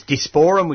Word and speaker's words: Disporum: But anyhow, it's Disporum, which is D-I-S-P-O-R-U-M, Disporum, so it's more or less as it's pronounced. Sicilii, which is S-E-S-S-I-L-E.